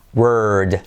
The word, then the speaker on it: word: pero en este caso, esta OR suena también R, ¿vale? Word.